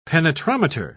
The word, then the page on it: penetrometer: British English